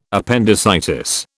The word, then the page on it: appendicitis: British English